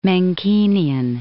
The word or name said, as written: Menckenian